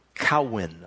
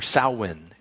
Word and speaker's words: Samhain: And there's this word name, and for us it would be Samhain, but it's pronounced Cowin, or Samhain is how it's pronounced.